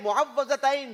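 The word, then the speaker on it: मुअव्विज़तैन: सही प्रोनाउंसिएशन है मुअव्वलतैन।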